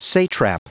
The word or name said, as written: satrap